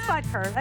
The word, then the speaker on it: Butker: It is Butker. I said it right.